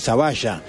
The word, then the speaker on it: Zavalla: Sí, como él nos dijo, se pronuncia Zavalla, en la provincia de Santa Fe.